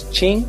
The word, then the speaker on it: Tim: And the first time I went there, as soon as I got off the plane, everyone was like, oi Chimino, because basically Tim, pronounced in Brazilian Portuguese, is Ching.